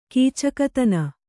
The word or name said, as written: ಕೀಚಕತನ